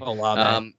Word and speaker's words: Olave: Like, Chris Olave. Or Olavi, or however you say his name. – Olave.